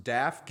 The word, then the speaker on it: DAF: I don't even know if I'm saying this right, Thomas. DAF giving, D-A-F giving. – Yep. You got it.